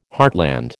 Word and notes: heartland: American English

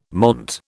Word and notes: monte: British English

